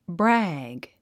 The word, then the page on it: brag: brǽg; ブラァグ